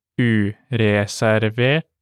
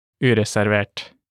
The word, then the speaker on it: ureservert: Let's break them down by syllable. Reservert, reservert, and ureservert, ureservert. Let's now imagine you want to buy a reserved seat ticket.